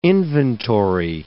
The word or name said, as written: inventory